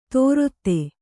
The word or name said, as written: ತೋರೊತ್ತೆ